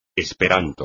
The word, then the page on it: esperanto: espagnol